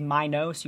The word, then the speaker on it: Minos: I'm saying Minos. That's how I was taught to pronounce it. There's also Minos. I think the correct pronunciation is Minos. You put some stank on that O.